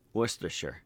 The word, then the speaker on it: Worcestershire: Why are you saying R? Worcestershire. – Worcestershire. – Yeah, there we go.